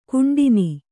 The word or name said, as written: ಖಂಡನೆ